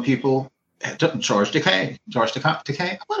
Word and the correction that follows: Takei: George. Oh, Takei. I said it wrong earlier.